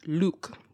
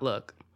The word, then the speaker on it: lewk: spelled L-E-W-K, pronounced Luk, not look.